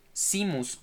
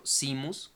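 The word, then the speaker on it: Seamus: No sé cómo se pronuncia, es S-E-A-M-U-S. E Yo siempre le he dicho Simus, como si fuera el mar. Sí. – Simus. Simus. – Simus o Simus, Es que Simus, como Simus Finnegan de Harry Potter.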